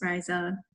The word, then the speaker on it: Raiza: like Raiza, Riza, Rizzo, all sorts of different iterations. And, I usually just say, it's just Raiza, and I'll correct the person until they get it right.